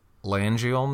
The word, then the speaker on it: laryngeal: There's this thing in all animals called the recurrent laryngeal nerve. I'm probably saying it wrong. – No, actually, that was right.